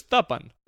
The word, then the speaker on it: Thuppen: But lately, I've been saying my name how like it was intended, which is Thuppen. And I like Thuppen.